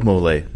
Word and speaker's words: mole: It was like a rice and something with like mole. Isn't that how you say it? Mole? – Mm-hmm.